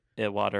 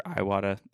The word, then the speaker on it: Iwata: But if you say Iwata or Iwata, I'm not going to hold it against you.